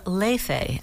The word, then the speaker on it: Leife: And before we heard that song, we heard the singer Leife, and that's how you pronounce it.